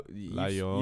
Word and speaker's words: Llorona: It's two L's, and two L's in Spanish is pronounced more like a Y. – Yeah, I know. La Llorona?